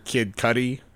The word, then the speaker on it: Kid Cudi: Kid Cudi.